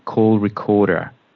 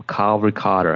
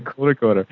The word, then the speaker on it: Call Recorder: Then I make sure that both of us are on video, and I use a little program called Call Recorder, or Carl Recorder, if you have an American accent. I really need to work on that. – Don't do that again. – You said it right the first time.